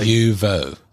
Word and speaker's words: IUVO: I'm not going to pronounce this right. – IUVO. – IUVO, thank you.